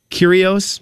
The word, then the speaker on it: Kyrgios: He played against Nick, I still don't know how to say this guy's name, Kyrgios, I believe is how you say the guy's name.